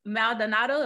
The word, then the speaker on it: Maldonado: So Njeri, oh snap, Maldonado, am I saying it right? – That was right.